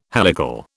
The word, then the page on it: helical: British English